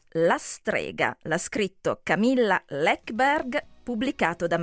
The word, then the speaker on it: Läckberg: Sottolineo che si pronuncia Läckberg, perché noi in Italia, devo dire, anche io fino a qualche tempo fa la chiamavo Läckberg, e invece insomma lei insiste nel dire guardate che il mio cognome è Läckberg, e quindi ok.